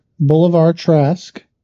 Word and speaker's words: Bolivar: I believe you said his name wrong. – Bolivar Trask. – Okay, I'm not hearing you right. – It's fine. – Because it sounds like you're saying Boulevard. – No D. Bolivar Trask in the comics is one of the most evil humans to human.